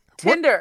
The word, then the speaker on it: Tinder: You understand how to pronounce Tinder now. – Tender, tender. I'm s- tender, nope. – tender, tender steak, tender t- the app. Tinder. What? – Ten. Can you say ten? – Ten.